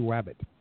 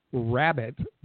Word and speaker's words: Rabbit: Not in the lineup for the Jacksonville Icemen is Wacy Wabbit. He will not be playing. I'm sorry. – Every time his name is actually Wacy Rabbit, but it's impossible to say his name without saying Wacy Wabbit.